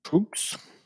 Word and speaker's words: Tröegs: Tröegs. Pronounced. There's a thing. I can't play it on air. – On this episode, rhymes with rogues. – Rogues. So Tröegs. Oh, we were right the first time.